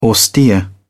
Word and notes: austere: British English

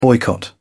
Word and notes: boycott: British English

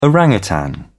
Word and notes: orang utan: British English

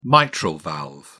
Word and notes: mitral valve: British English

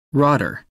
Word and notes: rotter: American English

